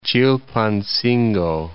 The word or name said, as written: Chilpancingo